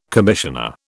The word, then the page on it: commissioner: British English